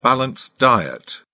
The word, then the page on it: balanced diet: British English